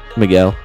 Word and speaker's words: Miguel: Miguel.